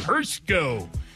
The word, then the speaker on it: Hirschko: I like saying that name, Hirschko. It doesn't look like Hirschko if you look at the way it's spelled, but Hirschko connected on a career-high long 45-yarder, 10:38 left in the first half to extend Ohio's lead 13-7.